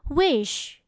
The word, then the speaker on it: wish: wish wish wish พรนะคะคำนี้เนี่ยออกเสียงดีๆนะคะเพราะว่าถ้าเกิดว่าเราเผลอไปกระแทกเสียงเฉอะเฉอะท้ายคำเนี่ยมันจะกลายเป็น witch